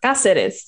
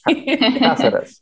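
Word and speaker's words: Cáceres: It's House of the Dragon is filming in Spain and in Caceres. I'm not sure how to say it. And just, I thought it was funny. Like the Caceres, sorry. – Caceres, okay.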